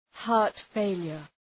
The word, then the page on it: heart failure: ‘hɑ:rt,feılər